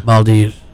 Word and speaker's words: Maldives: Maldives.